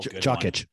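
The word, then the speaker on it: Jokić: Is Kitch, how do you say his name? Donkic? – Oh, Jokic. Good one.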